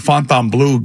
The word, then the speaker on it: Fontainebleau: There's also a Fontainebleau in Miami and now here, right? – That's how you say it. And the Fontainebleau got killed here because for two of Fiji waters and a liter of coffee, $72.